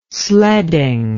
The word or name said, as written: sledding